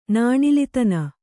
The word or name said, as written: ನಾಣಿಲಿತನ